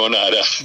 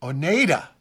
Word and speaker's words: Oneida: Rimaji, katakana, Japan, they would say Oneida, because the E-I has an A sound. Yeah, long A sound.